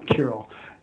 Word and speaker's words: Kirael: Well, it's spelled K-I-R-E-E-L, Kirill.